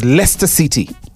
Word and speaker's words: Leicester: I had a friend of mine, Bradji, call me on radio and say, dude, it's pronounced as Leicester City.